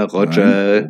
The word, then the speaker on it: Roger: Iroga, ja, Roger. Nein, genauso wie ein John Willemsen, John Willemsen ist. Alter Hamburger Tradition, englische Vornamen äh, nehmen, aber deutsch aussprechen.